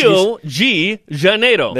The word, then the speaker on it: Rio de Janeiro: How do I say that in Portuguese? – Rio de Janeiro. – There you go. – Yeah, that's how you say it in Portuguese.